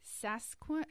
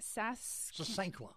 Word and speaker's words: sasanqua: Is it Sasquatch? How do you say that? – Sasanqua. – Sasanqua.